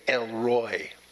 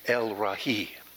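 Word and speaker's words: El Roi: You will hear preachers say El Roy. Sounds southern to me. El Roy. But it is the Hebrew pronunciation is much closer to El Ra'i. There it is. El Ra'i. Everyone say El Ra'i.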